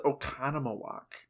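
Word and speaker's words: Oconomowoc: It's pronounced Okonomowoc.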